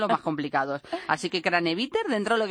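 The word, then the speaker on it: Kranevitter: Al fin y al cabo, al ser argentino, pues se lee cómo se pronuncia, o por lo menos así lo hacemos, aunque sea de origen austriaco, alemán, lo que sea. Para nosotros es Craneviter.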